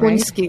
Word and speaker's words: Slunjski: Am I pronouncing that Slunjski.